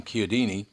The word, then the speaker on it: Chiodini: and John Chiodini. That's interesting that he pronounces the name properly. He pronounces it the Italian way.